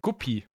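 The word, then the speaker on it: Guppy: Phil, kennst du äh, einen Gubbi? Ein Guppi. So würde ich es jetzt nicht aussprechen, aber ja.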